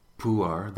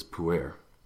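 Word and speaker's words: Pu'er: A friend of mine had given me a tea called puar. That's the Chinese translation, the pronunciation. The more Americans pronounce it as puer.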